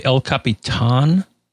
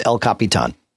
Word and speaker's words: El Capitan: So I'm assuming it's El Capitan. – Not tan. – Not tan. – Baton. – Ton. That's how it's pronounced.